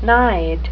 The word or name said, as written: nide